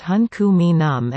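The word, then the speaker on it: hən̓q̓əmin̓əm̓: The name of the Musqueam language is pronounced Hun Ku Mi Num, and in the indigenous font it has characters that appear similar to inverted S with accents over four other letters.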